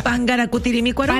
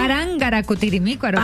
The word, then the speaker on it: Parangaracutirimícuaro: Parangaracutirimícuaro. – ¿Pangaracutirimícuaro? – Parangaracutirimícuaro.